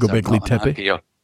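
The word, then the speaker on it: Göbekli Tepe: This was the reason for backfilling uh, Topepi Gepi. I, I probably s- pronounced that wrong because Gobekli Tepe. – Yeah, that's the place.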